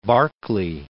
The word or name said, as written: Berkeley